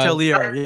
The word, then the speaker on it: hotelier: Well, the main thing was, yeah, they were much like Anakin Skywalker and hated sand, because Mr. Kime was a hotelier, and am I saying that right? – Yeah, hotelier. Yes.